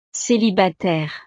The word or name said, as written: celibataire